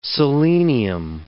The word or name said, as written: selenium